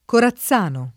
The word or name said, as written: Corazzano